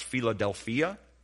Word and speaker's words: philadelphia: It looks a little bit like Philadelphia. It'd be pronounced Philadelphia.